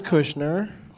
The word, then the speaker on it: Kushner: How do you say Kochner? Crooked Can? – David Kushner.